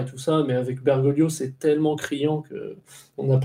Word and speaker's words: Bergoglio: Et comme nombre d'Argentins, il est des origines italiennes, donc le G, on le prononce pas, c'est Bergoglio, c'est un L. – Bergoglio, ok.